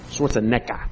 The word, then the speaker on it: Schwarzenegger: Schwarzenegger, I guess, is how you would pronounce it properly.